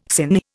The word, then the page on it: sinh: Indian